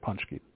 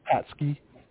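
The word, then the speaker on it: Pączki: I love a good Punchki. And no, it's not Patsky. It's Punchki. Punchki is how it's pronounced.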